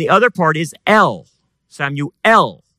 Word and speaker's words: Samuel: Samuel, we say it in English, Samuel, but in Hebrew, the way you pronounce Samuel's name is Shema El.